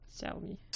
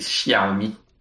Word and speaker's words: Xiaomi: Ça s'écrit avec un X. Comme ah. le personnage de Genshin Impact, Xiao. Xiaomi. C'est Xiaomi.